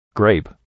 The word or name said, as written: grape